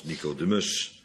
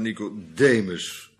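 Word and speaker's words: Nicodémus: Dat doen ze anders nooit in de Bijbel, accenten leggen, maar ze zijn bang dat wij het uitspreken als Nicodemus. En dat is misschien wel familie, maar weet ik ook niet. Waarschijnlijk familie van Onesi. Mus, ja, goed zo. Ja. Dus niet Nicodemus, maar Nicodemus. Nicodemus, de overwinnaar van het volk of zoiets betekent dat.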